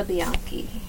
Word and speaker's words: Bianchi: Anna Sophia Bianchi. – Yes. – V-I-A-N-C-C-I? – C-H-I. – C-H-I.